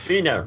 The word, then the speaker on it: Vina: Vina rhymes with Wiener.